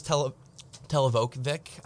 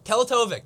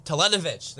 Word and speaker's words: Teletovic: So the first one I'll talk to you, about is him going to the Milwaukee Bucks for a package of Milos Teletovic. I'm butchering his fucking name. Teletovic. There we go. Malcolm Brogdon. – Teletovic.